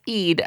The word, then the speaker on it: Ede: He tweeted me and was like, it's not Edie, it's Ede.